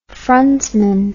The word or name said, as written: frontsman